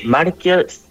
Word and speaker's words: Marquia: Sí, Marquia, que se escribe con Q. – Marquia. – IA.cl, sí. – .cl, ya, sin una U entre medio, como diría el idioma español. Marquia, así nomás.